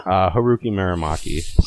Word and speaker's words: Haruki Murakami: Haruki Murakami.